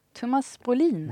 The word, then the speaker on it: Thomas Brolin: Thomas Brolin.